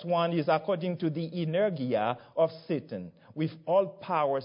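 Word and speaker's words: energeia: The Greek word translated by power or working here sounds a lot like the English word that derives from it. Let me pronounce it in Greek. Energia.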